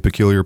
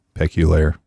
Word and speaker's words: Peculiar: Some people say peculiar, but it's pronounced peculaire.